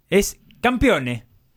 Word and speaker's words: campione: Puede gritar nuevamente, Pepe, campioni. – ¿Con qué? – ¿Por qué? Porque juega la Copa Italia, la final. – Qué bien pronunciado el italiano, ¿cómo sería? – Es campione. – Campione.